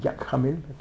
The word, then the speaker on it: Jacques Hamel: ¿Cómo se pronuncia? Jacques Hamel. ¿Que alguien habla francés? Jacques Hamel. Así, más o menos.